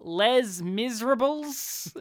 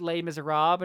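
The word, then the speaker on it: Les Misérables: you saw a Broadway production of Les Miserables? And he's like, it's pronounced Les Miserables. And he goes, oh, I'm sorry, I don't know how to speak no fancy French words.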